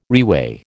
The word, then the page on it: reweigh: American English